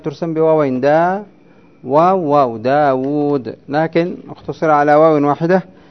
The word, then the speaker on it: داود: وكالواو التي يلفظ بها بين الواو والدال في داود، داود دا حركة بعدها ألف سكون، بعدها واو مضمومة حركة، بعدها واو تنطق ولا تكتب سكون. نعم. وكالألف التي يلفظ بها ها تحسب واوان. نعم. كم تكتبها أنت الرسم من حيث الرسم؟ واو واحدة لكن من حيث النطق، وتنطق واوين، واو متحركة بحركة وواو ساكنة بسكون.